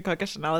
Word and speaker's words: Coco Chanel: Because you like had no idea who Coco Chanel is, and now you're like, did I say her name right? – Yeah. I'm looking over... looking over for approval from you. – You did good, girl.